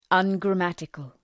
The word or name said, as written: ungrammatical